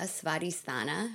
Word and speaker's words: Svadhisthana: And creativity focuses on the second chakra, Svadhisthana. Am I saying that right? – Yeah, you are.